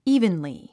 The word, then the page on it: evenly: íːvənli; イーブンリィ